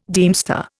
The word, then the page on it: deemster: Indian